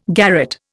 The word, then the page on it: garret: Indian